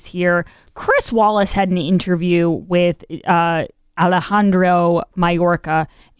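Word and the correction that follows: Mayorkas: and it's getting a lot of play. – Mallorcas. – I know. Some people are telling me I got to pronounce the S. I keep forgetting.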